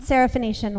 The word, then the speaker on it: Syrophoenician: First, in Mark, we have the Syrophoenician woman. Did I say that right? Mm-hmm. Syrophoenician woman.